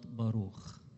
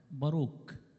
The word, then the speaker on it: باروخ: سفر باروخ عباره عن خمس اصحاحات، وباروخ كلمه عبريه معناها بركة، باروك، يعني الخاء والكاف والشين، الحروف دي بتتبادل في النطق للحرف الواحد. فباروخ يعني باروك، يعني بركة أو مبارك.